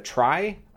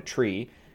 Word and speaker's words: trie: But this paper introduces a variant of I'm not sure how popular it is, a data structure called a try. I believe that's how I pronounce it. But I've heard some people call it a tree, which is confusing because that's also a sort of other node-based data structure. But it's spelled T-R-I-E to disambiguate. And the variant of it is called a dog, which is D-A-W-G.